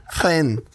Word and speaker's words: Fresnes: Du kan inte stavas Fresnes och uttalas Fren. Men, det är det värsta jag hört. – Fren. – Alltså F-R-E-N-N skulle jag stava det.